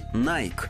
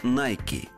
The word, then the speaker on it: Nike: Мы привыкли говорить Nike, хотя на самом деле правильное произношение именно Nike – Впрочем, во всем остальном компании везет.